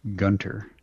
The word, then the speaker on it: Gunter: Pat Gunter. – Yes. Rhymes with Hunter.